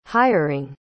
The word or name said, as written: hiring